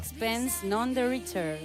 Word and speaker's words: Sixpence None the Richer: Sixpence None the Richer.